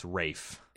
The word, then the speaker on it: Ralph: I just said Ralph Fiennes, didn't I? It's Rafe.